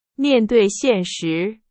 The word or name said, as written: 现实